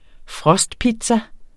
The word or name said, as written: frostpizza